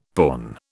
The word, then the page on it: porn: British English